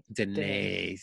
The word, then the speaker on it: Danae: Danae, I never really got how to say your last name correctly. – It's Danae. – Danae. Mm-hmm. Morgyn Danae. Yes.